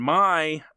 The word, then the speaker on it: Maj: It's spelled M-A-J, but it's pronounced Maj, and they get married in 1954, and they would be married for the rest of their life, and he never...